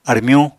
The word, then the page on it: armyuñ: Apatani